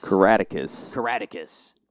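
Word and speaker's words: Caractacus: What did I say his name was? – Catechus. It's like a Roman... Is it Roman? Catechus. – Caraticus. So Grandpa Potts is the father of Caraticus Potts, obviously.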